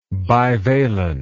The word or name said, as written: bivalency